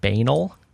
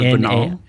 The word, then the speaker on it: banal: For years, I've said the word banal. – Instead of banal? – Exactly. Instead of banal. – Gotcha. – Yep. That was embarrassing when I found that out because, nope, it does not rhyme with anal.